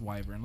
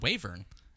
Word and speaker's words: wyvern: Pronunciation of wyvern. Wyvern. – Oh, wow. I've always heard wavern. – Yeah, it's wyvern.